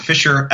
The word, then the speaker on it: Fischer: So my name's Ford Fisher, F-I-S-C-H-E-R. There is a silent C in there.